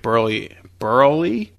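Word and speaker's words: Buehrle: Dude, I read on SportsKey that the Sox are going to give Burley That's right.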